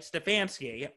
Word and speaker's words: Stefanski: And then going into it, Cleveland, that didn't practice at all that week because COVID, and their coach, Kevin Stefanski – that's how you say his name, right? Stefanski. – Stefanski, yes.